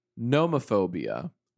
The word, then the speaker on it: nomophobia: Aaron, your next phobia is nomophobia or nomophobia. And I will spell it for you. – N-O-M-O.